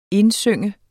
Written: indsynge

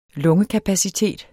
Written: lungekapacitet

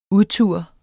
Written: udtur

